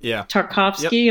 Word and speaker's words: Tarkovsky: And I think visually he was very influenced by Tarkovsky.